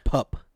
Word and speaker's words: pup: It's pub Dude's play. Say it correctly if you're going to announce it. – It's pub play. What is it? pub play. Pup. – Yeah, pup.